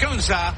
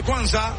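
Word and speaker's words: Cunha: La está reclamando y la recibe Kunsa. Con H muda al final del apellido.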